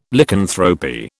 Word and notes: Lycanthropy: British English

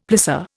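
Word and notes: plissé: Indian

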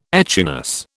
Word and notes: echinus: British English

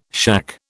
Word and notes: shack: British English

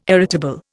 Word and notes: irritable: Indian

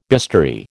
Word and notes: bistoury: American English

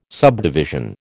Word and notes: subdivision: American English

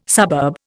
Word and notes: suburb: Indian

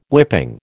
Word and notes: whipping: American English

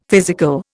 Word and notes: physical: Indian

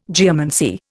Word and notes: geomancy: Indian

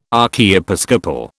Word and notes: archiepiscopal: British English

